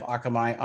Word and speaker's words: Akamai: I don't know if I'm pronouncing it right, but I have Akamai on every single website.